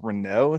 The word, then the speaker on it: Renault: One quick interjection, the team that he did win his F1 championships with is pronounced Renault. It's a French company.